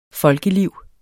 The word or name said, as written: folkeliv